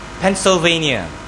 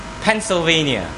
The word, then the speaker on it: Pennsylvania: He's from Pennsylvania. I got it right this time, isn't it? I used to pronounce it wrongly. Pennsylvania.